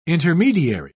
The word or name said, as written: intermediary